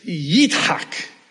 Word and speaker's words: Yitzhak: Isaac, we pronounce it in English. It's Yitzhak in Hebrew. It's Yitzhak in Arabic.